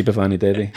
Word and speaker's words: Bhavani Devi: And she came up the next time, she said, it was a good attempt, but next time just say Bhavani Devi.